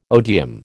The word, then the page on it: odeum: American English